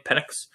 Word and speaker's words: Penix: Penix, is that how you say his name? Penix? – Yeah, he's fun.